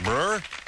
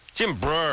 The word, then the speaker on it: Breuer: Now, I say Brewer, but is it like Brewer? – Brewer. – Brewer. – Brewer. – Yeah, because it's not normally, it's not spelled how a Brewer is spelled, but it's like Brewer.